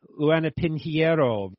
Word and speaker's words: Luana Pinheiro: Luana Pinheiro versus Sam Hughes. If I mispronounce anything, linguists, make sure you let me know, okay? – Seems like you got that one right.